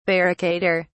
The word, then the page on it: barricader: British English